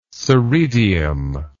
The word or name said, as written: soredium